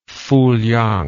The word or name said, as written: Fowliang